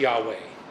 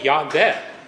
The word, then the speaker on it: Yahweh: And many argue, well, it should be pronounced Yahweh, right? That would be spelled Y-A-H-W-E-H. But there's a whole contingent that says, no, it should be Yahveh, Y-A-H-V-E-H.